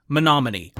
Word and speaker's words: Menominee: I won't be repeating my mistaken pronunciation, but I now understand that the town is pronounced Menominee.